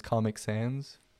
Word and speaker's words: Comic Sans: Okay, so and and one of them is Comic Sans. Am I saying that correctly? – Correct.